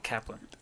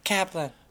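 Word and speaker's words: Caplan: And then my girl, my lead actress, Lizzie Chaplin, baby. Oh, day. – Kaplan. – Low. Kaplan.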